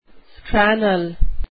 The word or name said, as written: scrannel